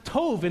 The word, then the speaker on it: tov: Well, I'm here to tell you, you've probably been pronouncing it wrong like the whole time. The word is pronounced tov, and it actually appears nearly 700 times in the scriptures.